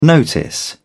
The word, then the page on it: notice: British English